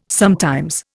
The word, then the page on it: sometimes: Indian